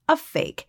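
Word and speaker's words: PHEIC: as our colleague Helen Branswell tells us, the acronym's pronounced, a fake.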